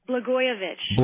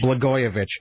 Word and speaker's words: Blagojevich: How do you pronounce this guy's name? – Blagojevich. – Blagojevich.